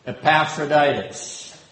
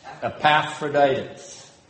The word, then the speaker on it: Epaphroditus: Let's get the proper pronunciation. Epaphroditus. Epaphroditus. Now, why does Paul use this military term? – Christianity is a fight.